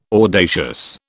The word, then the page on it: audacious: British English